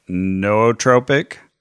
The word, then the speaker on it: nootropic: No, but not new like N-E-W either. – This is new like Chattanooga. – Yes. – N-O-O. – Newt. – And look, some people say it's pronounced nootropic. – Or nootropic. – Or nootropic. – Nootropic. That's what the lady on Google says.